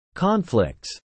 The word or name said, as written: conflicts